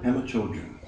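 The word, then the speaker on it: Pema Chödrön: Pema Chodron.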